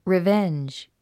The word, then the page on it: revenge: rivéndʒ; リィベェンジ